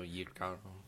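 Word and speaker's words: jícaro: Semilla de Jicaro? Yicaro? – The J is ye, so Yicaro.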